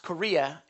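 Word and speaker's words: Kyria: Others think that the letter was addressed to a person, and it's actually pronounced Korea, which is lady in Greek.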